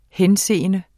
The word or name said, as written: henseende